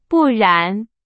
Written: 不然

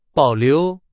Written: 保留

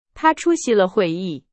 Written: 出席

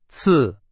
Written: 次

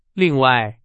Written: 另外

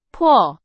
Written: 破

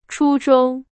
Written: 初中

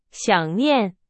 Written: 想念

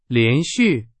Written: 连续

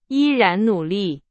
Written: 依然

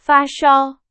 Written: 发烧

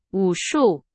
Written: 武术

